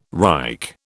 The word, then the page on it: Reich: British English